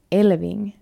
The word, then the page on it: Elfving: finlandssvenska